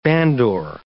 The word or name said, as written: bandore